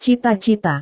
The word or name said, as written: cita-cita